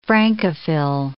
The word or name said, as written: Francophil